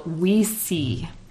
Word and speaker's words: Ouisi: It's spelled O U I S I. So, it's a pun on W E S E E, but with the French and Spanish words for yes, we and see